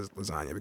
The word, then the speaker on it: lasagna: The G in lasagna. Nobody says lasagna. – Right. – The G is silent.